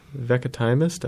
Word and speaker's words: Veckatimest: Veckatimest? I don't know how to pronounce it. – Veckatimest? V E C K A T I M E S T.